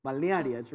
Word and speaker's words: Balnearia: Okay, it's spelled B-A-L-N-E-A-R-I-A. And if you pronounce it three times fast, you're doing better than I am. Balnearia.